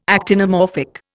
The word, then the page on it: actinomorphic: Indian